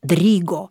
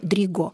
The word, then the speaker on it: Дриго: В связи с этим мы делаем ударение в этой фамилии Дриго. Хотя поклонники балета, поскольку да, французские превалируют в балете, делают ударение Дриго. Но остановимся на том, что он по национальности итальянец. Ударение будем использовать в этой части фамилии.